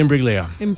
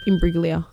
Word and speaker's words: Imbruglia: Natalie Imbruglia. – Put it down. Put it down. I'll give you that. – Soft G. – Soft G. – Soft G. – Imbruglia. – Soft G. – Imbruglia.